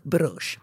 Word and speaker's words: Bruges: Jag vet inte om det uttalas så. – Nej, men nu gör det. I den här podden så har vi som policy att man väljer själv. – Att man väljer själv och det uttalas brush.